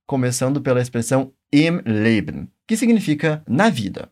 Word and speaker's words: im Leben: Preste atenção que quando nós falamos im, é importante fechar bem os lábios para pronunciar a letra M. Isso é algo bem característico do alemão que não prestamos tanta atenção no português.